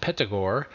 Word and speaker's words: Pythagore: Thus we meet with Peter Gower, evidently derived from Pythagore, pronounced Petagore, the French for Pythagoras.